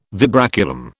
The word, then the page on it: vibraculum: British English